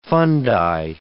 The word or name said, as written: fundi